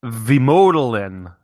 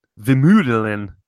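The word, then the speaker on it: Vemödalen: Vemodelin, V-E-M-O, and the O has accent, like a long O. It's not an umlaut, but it's Vemodelin, V-E-M-O-D-A-L-E-N.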